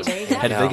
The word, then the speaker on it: Hedwig: I'm going to put the German pronunciation. Hedwig and the Angry Inch.